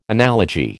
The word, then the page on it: analogy: American English